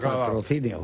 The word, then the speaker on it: Patrocinio: Lo ha dicho bien Eduardo. ¿Eh? Eduardo lo ha pronunciado patrocinio.